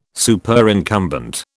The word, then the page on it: Superincumbent: British English